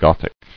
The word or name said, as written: Gothicizing